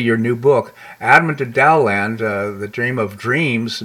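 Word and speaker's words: Taoland: I guess it's spelled Dowland. – Uh, it's pronounced Dowland, uh, Taoism.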